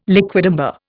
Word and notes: liquidambar: Indian

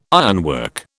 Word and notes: ironwork: British English

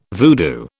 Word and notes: voodoo: British English

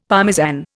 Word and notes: Parmesan: Indian

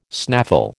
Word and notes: snaffle: American English

